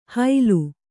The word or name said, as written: ಹೈಲು